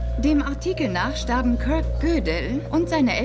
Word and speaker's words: Gödel: Kirk Goodell. – Gödel. Das wird Gödel ausgesprochen, weil da diese frechen Punkte auf dem O sind.